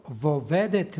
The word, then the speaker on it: vovedet: Vovedet je složenica sastavljena od glagola, od oblika glagola vesti i predloga. Između početnih suglasnika dodaje se izgovorno o radi prenošenja preciznijeg značenja. Nešto slično imamo i u molitvi gospodnjoj. I ne vovedi nas vo iskušenije. Dakle, izgovor je vovedet.